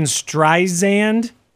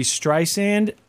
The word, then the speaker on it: Streisand: I guess that it was saying Streisand and it should be Streisand.